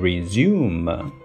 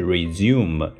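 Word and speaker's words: résumé: 对了，简历这个词呢，r e s u m e 加一飘，哎，不要读成 resume，因为如果你按照英语的发音方式，它就是 resume。但是呢，因为它是一个法语词，所以我们要把它发成 resume，resume，resume，是不是一加一飘又读成了 a 这个音呢？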